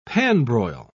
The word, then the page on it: pan-broil: British English